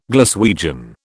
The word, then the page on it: Glaswegian: British English